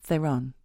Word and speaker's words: Theron: I thought like Charlize Heron. Theron. – Charlize Theron.